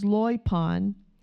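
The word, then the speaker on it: loipon (λοιπόν): This word is loipon. Loipon. The accent is on the second syllable.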